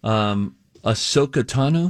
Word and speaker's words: Ahsoka Tano: Ahsoka Tano, am I saying the name right? – Yeah.